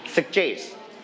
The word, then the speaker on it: suggest: Suggest. One more time. Suggest. Very good. Alright. Together. Suggest. Straight on the second syllable.